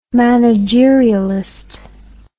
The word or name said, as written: managerialist